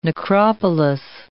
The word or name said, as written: necropolis